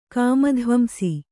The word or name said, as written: ಕಾಮಧ್ವಂಸಿ